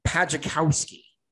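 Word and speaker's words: Pajaczkowski: They thankfully have a pronunciation guide. I'm going to say Pajakowski. Pajakowski.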